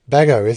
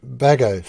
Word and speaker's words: Bagehot: And another book I think that is very important, The English Constitution by Walter, I think it's pronounced Bagehot, Bagehot. And I'm part way through, Bago, is it? Okay. And yeah, Bago, fascinating reading.